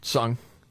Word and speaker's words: Ngan: Because it's spelled N-G-A-N, Sung, is how you pronounce it, and then H-U-Y-N-H, win.